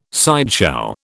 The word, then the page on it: sideshow: British English